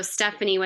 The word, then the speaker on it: Stefanie: So Stefanie with an F um, Overstreet on Instagram.